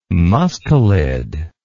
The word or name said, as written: mustelid